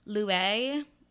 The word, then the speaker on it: Luai: Louie. It is Arabic. It is spelled L-U-A-I.